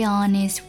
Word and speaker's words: honest: But I know how I feel.但我清楚我的感受。第三句 To be very honest with you, love. To be very honest with you, love. To be very honest with you, love. 这里要提一下 Honest 这个单词前面的 H 是没有发音的 Honest，但是歌手在演唱的时候唱出了一个这是不对的，要注意一下 Very honest 前面那个不发音，跟 very 形成了外加 z 连读。